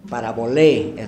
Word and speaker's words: parabolé: Muy bien, parabolé es la palabra, termina en la E larga como, como una N.